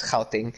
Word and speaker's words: Gauteng: Gauteng. – Sorry, yeah, I mean, you're a bit overpronouncing it, but that's good. You're actually doing, yeah. Helping. Yeah. Yeah, you did it right there.